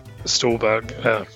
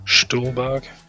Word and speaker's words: Stuhlbarg: Yeah, and Michael Stuhlbarg, I'm going to butcher that name because it's German, Stuhlbarg, was great as Dimitri, like we said before.